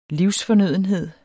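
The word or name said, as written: livsfornødenhed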